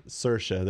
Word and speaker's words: Saoirse: Saoirse, there you go.